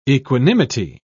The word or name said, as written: equanimity